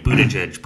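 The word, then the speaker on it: Buttigieg: Boot edge edge, boot edge edge, boot edge edge, boot edge edge. Two great mnemonic devices to learn how to say the guy's name.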